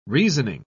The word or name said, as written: reasoning